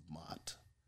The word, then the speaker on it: Maat: M-A-A-T, the principles of Maat. Or Maat. That's how it's pronounced.